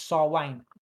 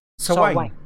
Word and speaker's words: Samhain: It is Samhain, but you pronounce it Sarwain. – Sarwain.